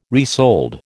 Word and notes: resold: American English

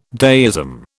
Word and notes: deism: British English

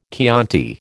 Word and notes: Chianti: American English

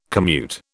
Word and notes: commute: British English

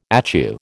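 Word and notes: étui: American English

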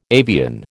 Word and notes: avian: American English

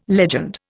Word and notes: legend: Indian

